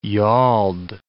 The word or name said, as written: yauld